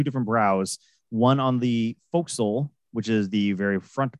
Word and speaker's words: forecastle: But it's pronounced forecastle.